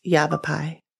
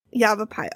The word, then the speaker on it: Yavapai: And I hope I'm saying that right. – I Googled it. It's Yavapai. – Yavapai.